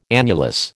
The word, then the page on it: annulus: American English